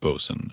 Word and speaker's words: boatswain: I mean, it's pronounced bosun, and you could spell it B-O-S-U-N, but in Treasure Island and most other sources, it's spelled B-O-A-T-S-W-A-I-N. You'd think it would be pronounced boatswain, but it's bosun.